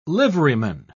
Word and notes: liveryman: British English

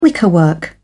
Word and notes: wickerwork: British English

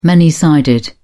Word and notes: many-sided: British English

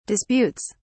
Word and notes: disputes: British English

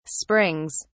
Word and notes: springs: British English

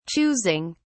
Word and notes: choosing: British English

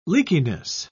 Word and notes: leakiness: British English

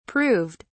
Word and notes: proved: British English

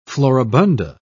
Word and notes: floribunda: British English

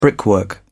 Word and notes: brickwork: British English

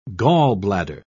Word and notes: gallbladder: British English

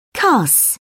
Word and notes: cuss: British English

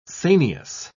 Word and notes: sanious: British English

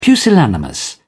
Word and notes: pusillanimous: British English

